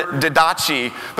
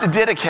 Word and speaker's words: Didache: And it looks like the word didache, but it's actually pronounced didache.